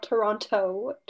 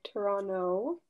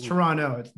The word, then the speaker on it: Toronto: Not Toronto. Toronto. – It's Toronto. There's that T does not exist in this.